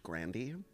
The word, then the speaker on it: Grande: and just to circle back to Ariana Grande, okay. Do you know that her last name is actually pronounced Grandy? – That's so funny.